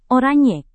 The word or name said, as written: oranye